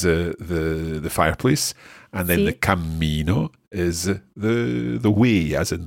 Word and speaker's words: cammino: Cammino. – So I hope you can hear the difference there in Francesca's pronunciation. Cammino, one M. Cammino, with two M's and a little bounce in the middle.